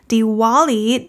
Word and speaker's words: Diwali: 第二个加剧了德里空污的是一个印度节日 Diwali, D-I-W-A-L-I, 它有两个常见的发音 Diwali, W 的音，另外一个是 V 的音 Diwali, Diwali, Diwali 这个节日还有另一个名字，主要通用在印度南部以及东南亚 Deepavali，有几个常见的中文翻译，排灯节、土妖节、光明节、印度灯节等等。